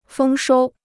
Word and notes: 丰收: fēng shōu

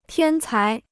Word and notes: 天才: tiān cái

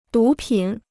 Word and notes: 毒品: dú pǐn

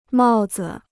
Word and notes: 帽子: mào zi

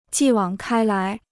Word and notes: 继往开来: jì wǎng kāi lái

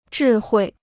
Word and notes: 智慧: zhì huì

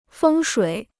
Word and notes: 风水: fēng shuǐ

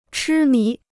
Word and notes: 痴迷: chī mí

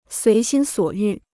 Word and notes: 随心所欲: suí xīn suǒ yù

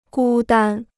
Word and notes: 孤单: gū dān

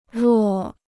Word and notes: 若: ruò

